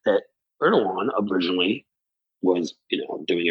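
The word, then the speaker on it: Erdoğan: And it's how how uh, Erdogan, whose name I always get wrong, John knows how to pronounce it. Erdogan. Erdogan, who uh, initially, because the Chinese are literally putting the Uyghurs, I think I'm pronouncing that right.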